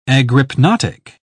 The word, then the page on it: agrypnotic: British English